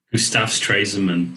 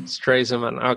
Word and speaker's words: Stresemann: Gustav Stresemann. – Stresemann, okay.